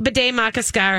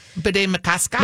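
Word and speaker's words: Bde Maka Ska: Bede Makaska, or however we're going to say it. I say it wrong. Bede Makaska. – Bede Makaska? – Bede Makaska.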